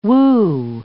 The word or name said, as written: Wu